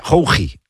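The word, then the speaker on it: Khoukhi: Boualem Kouki. – Qatar. Selección. – Igual se pronuncia Houji, de Qatar.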